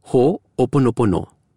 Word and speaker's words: Ho'oponopono: It's pronounced Ho'oponopono.